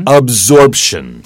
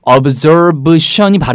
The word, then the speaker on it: absorption: A-B-S-O-R-B-E. Absorb. – Yeah. – 그게 이제 원형이죠. 흡수하다. 근데 이게 absorption이 발음이 힘들어요. – Yeah. – 그래서 B를 P로 바꾸는 이유는 뒤에 나오는 션 때문에 발음이 그런 거예요.